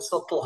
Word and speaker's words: subtle: And, did you know that Subtle has a silent B in it?